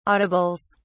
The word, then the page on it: audibles: British English